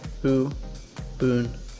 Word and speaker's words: Ubuntu: Some people say it like that. It's just Ubuntu. Just Ubuntu. Done.